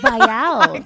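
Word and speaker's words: Viall: And then Nick Vile. – Is that how you pronounce his name? – Vile. – Yeah.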